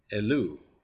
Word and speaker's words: Elul: And in Hebrew, the word is Elul, Elul. That's how you say it.